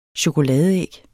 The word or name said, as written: chokoladeæg